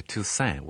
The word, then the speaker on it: Toussaint: My father used to say Toussaint without a T on the end.